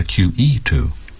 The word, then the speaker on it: QE2: You could also say The Queen Elizabeth II. – 哦，这么多种说法。 – And you could also say the QE too.